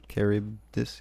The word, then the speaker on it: Charybdis: So that was the track Chronicles from the album In the Shadow of Paradise by, I'm guessing that is Charybdis. – Charybdis, yeah. – I believe it's Charybdis, yes.